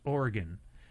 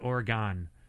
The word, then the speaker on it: Oregon: Until now, I finally realize how to say it's Oregon, and it's not Oregon.